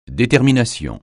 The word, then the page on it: détermination: francais